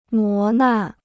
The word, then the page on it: 磨难: mó nàn